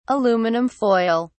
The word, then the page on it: aluminum foil: British English